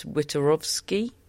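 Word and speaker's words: Wiktorowski: But she's a huge fan of her new coach, Thomas Witorowski. You're both nodding at me. Well done, me, for that pronunciation.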